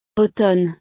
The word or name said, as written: automne